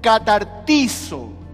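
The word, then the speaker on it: catartizo (καταρτίζω): Y la palabra griega que se traduce aquí para la palabra perfeccione es una palabra que se pronuncia catartizo. No sé si alguna vez usted escuchó esta palabra, catartizo.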